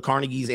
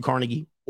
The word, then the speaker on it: Carnegie: And it referenced the Rockefeller, the ascent of the Carnegies, Andrew Carnegie. Or Carnegie, however you want to pronounce it, John D.